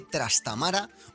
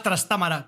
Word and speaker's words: Trastámara: Y luchaban por el trono y sus cascabeles Pedro I y su hermanastro Enrique de Trastámara o Trastámara. ¿Cómo depende. Te gusta pronunciarlo a ti? – A mí me gusta más Trastámara, que es mucho más musical, pero yo lo digo igual. Trastámara.